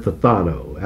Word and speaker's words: phthano: This is the word phthano. That's spelled P-H-T-H-A-N-O.